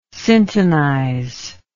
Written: syntonize

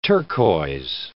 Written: turquoise

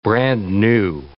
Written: brand-new

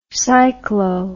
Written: cyclo

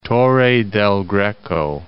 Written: Torre del Greco

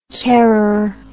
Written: carer

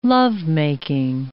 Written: lovemaking

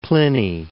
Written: Pliny

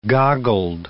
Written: goggled

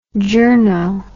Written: journo

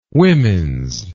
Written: womens

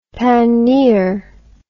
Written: paneer

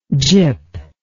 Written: gip

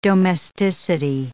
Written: domesticity

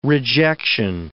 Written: rejection

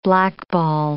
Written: blackball

